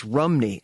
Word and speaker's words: Rhymney: And, of course, today Roger pronounces it the correct way. It's Rhymney, as all my Welsh friends tease me.